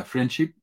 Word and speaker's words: Friendship: Chile se pronuncia diferente la SH. La SH en Chile es la ch y la CH es sh. Entonces, en Chile se dice friendship.